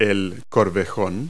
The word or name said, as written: hock